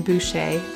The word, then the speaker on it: Boucher: You just search for my name as one word, Katherine Boucher. That's spelled B-O-U-C-H-E-R.